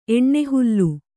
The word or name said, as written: ಎಣ್ಣೆಹುಲ್ಲು